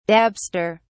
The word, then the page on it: dabster: British English